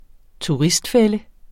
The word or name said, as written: turistfælde